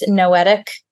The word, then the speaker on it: Noetic: Also, our website um, is a great way to um, find me, which is Noetic, uh, N O E T I C, rhymes with poetic, but with an N, mm-hmm.